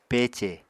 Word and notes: pece: Apatani

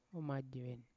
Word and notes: omajeven: Yawa

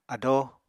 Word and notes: ado: Apatani

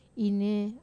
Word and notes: nei: Wamesa

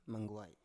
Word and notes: manguai: Biak

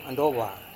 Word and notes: andowa: Serewen

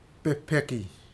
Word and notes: pehpeki: futuna aniwa

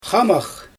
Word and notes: xamax: Xyzyl

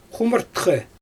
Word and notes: xumɯrtxɛ: Xyzyl

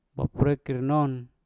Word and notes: baprekkir non: mpur

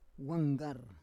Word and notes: wanggar: Roon

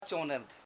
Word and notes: chonim: cho.nim

